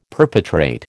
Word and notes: perpetrate: American English

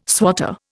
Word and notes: swatter: Indian

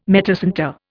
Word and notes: metacentre: Indian

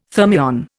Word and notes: thermion: Indian

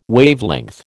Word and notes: Wavelength: American English

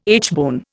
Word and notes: aitchbone: Indian

